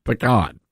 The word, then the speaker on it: pecan: Now, if you come at me with a pecan pie or a pecan pie, I'm going to turn your way because it's a pecan pie. That is the correct pronunciation, pecan.